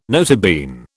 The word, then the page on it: nota bene: British English